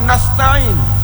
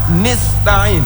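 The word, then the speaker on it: nasta'in (نستعين): Dan kasrah nun nasta'in, orang tersebut membacanya nista'in.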